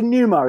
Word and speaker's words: pneuma: It's got a silent P. It's a pneuma is the word for spirit or breath.